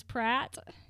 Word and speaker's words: Pratt: If you didn't catch it, my last name is Pratt. – Spelled the same in everything. – P-R-A-T-T.